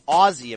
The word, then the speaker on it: Aussie: I know I'm saying it wrong. Anyone who listens to the wrestling show, it's Aussie apparently.